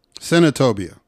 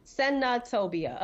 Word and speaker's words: Senatobia: What is the name of the... Centobia? – Senatobia. – Senatobia. Okay. – Yeah. – All right. I was talking to A. Senatobia.